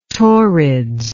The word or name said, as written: Taurids